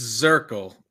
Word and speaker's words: Zirkel: So it's Zirkel, Z I R K E L.